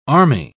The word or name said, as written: army